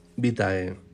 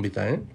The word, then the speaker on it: vitae: No es admisible la pronunciación currículum vitae con el segundo término acentuado en la penúltima sílaba.